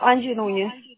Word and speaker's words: Angie Núñez: No, Angie Núñez. – Angie. Angie se escribe A-N-G-I-E.